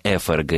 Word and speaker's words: ФРГ: И в результате, Ольга, правильное произношение ФРГ и ФСБ, но так вы практически уже и не услышите.